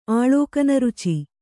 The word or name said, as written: ಆಳೋಕನರುಚಿ